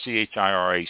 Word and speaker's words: Chirac: Chirac.